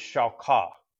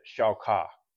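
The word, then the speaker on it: shakah (שחה): That word is shakah. It sounds like S H A W K A W if you were to pr- pronounce it, shakah.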